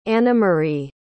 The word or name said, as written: anamari